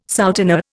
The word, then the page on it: souteneur: Indian